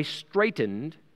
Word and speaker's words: straitened: But then he says, verse 50, and how am I straitened till it be accomplished? Straitened, guys, the word straight in English, we pronounce it the same, but two different words here.